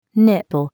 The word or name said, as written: nipple